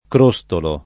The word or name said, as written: Crostolo